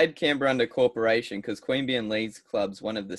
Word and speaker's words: Queanbeyan: Queanbeyan Leagues Club? – Queanbeyan, there you go.